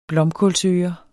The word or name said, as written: blomkålsøre